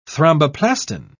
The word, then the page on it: thromboplastin: British English